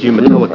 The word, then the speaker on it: Umatilla: I was impressed you pronounced Umatilla correctly.